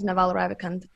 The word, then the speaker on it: Naval Ravikant: The Almanac of Neville, I don't know how to pronounce his name, Neville Ravikant, something like that. Mm-hmm. So this one was also Naval, like a brilliant book. – Naval Ravikant, yeah. – Naval, yes, that's the one.